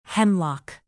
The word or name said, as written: hemlock